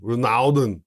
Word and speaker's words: Renaudin: I'm going to imagine that that is pronounced with a French accent. – Ronaldon.